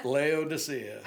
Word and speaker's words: Laodicea: now help me out here Laodicea. – Laodicea.